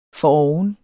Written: foroven

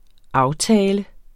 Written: aftale

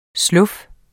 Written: slof

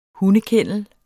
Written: hundekennel

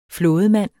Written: flådemand

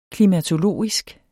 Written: klimatologisk

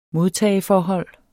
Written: modtageforhold

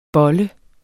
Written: bolle

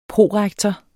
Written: prorektor

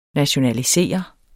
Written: NATIONALISERE

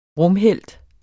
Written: rumhelt

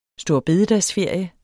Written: storebededagsferie

